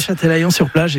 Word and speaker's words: Châtelaillon-sur-Plage: Alors, vous n'avez pas y resté très longtemps non plus, vous revenez en France et vous allez, je ne voudrais pas l'écorcher, à Châtelaillon-sur-Plage, c'est comme ça qu'on le prononce. – Oui, Châtelaillon-sur-Plage,